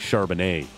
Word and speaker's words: Charbonnet: Yeah, Zach Charbonnet. – Hmm. – Which rhymes with Chardonnay.